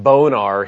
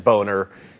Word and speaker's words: Boehner: His father pronounced it Bonar. His mother pronounced it Boehner.